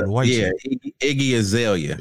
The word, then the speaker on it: Iggy Azalea: So I haven't seen you commenting on it, but Iggy Azalea, is that how you say her name? I don't know. – Iggy Azalea.